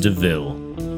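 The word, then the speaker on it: de Vil: It's spelled devil, but it's pronounced Deville.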